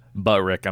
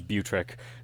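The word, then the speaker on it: Butrick: It's probably not pronounced Buttrick, is it? – Buttrick, I'm sure that's it. – I guess if there's one T, it's Buttrick.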